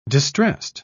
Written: distressed

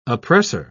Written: oppressor

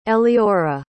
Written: eleora

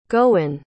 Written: gowyn

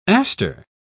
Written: aster

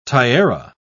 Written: tiara

